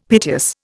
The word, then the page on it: piteous: Indian